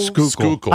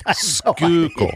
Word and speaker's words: Schuylkill: The Schuylkill River, is that what it's called? The Schuylkill? – Schuylkill. – I don't know how you pronounce it. – Schuylkill.